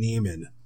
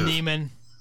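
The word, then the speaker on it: Niemann: So Ben Neiman is, you know, I even want to say it like how Seinfeld says Newman. Neiman. – Yeah. Neiman. Hello, so, Neiman.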